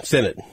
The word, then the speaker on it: Sennett: The Kansas State tight end Ben Sennett. Okay, like he's running for Senate, right?